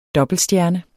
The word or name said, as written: dobbeltstjerne